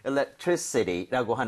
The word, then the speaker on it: electricity: So, electricity라고 하는 거, I에다 강세를 찍고 이렇게 발음을 하게 되면 전기라는 명사가 될 수가 있습니다.